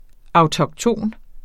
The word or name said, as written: autokton